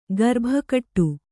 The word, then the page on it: ಗರ್ಭಗುಡಿ: Kannada; garbha guḍi